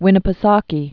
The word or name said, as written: Lake Winnipesaukee